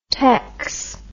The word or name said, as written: tex